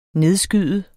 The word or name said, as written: nedskyde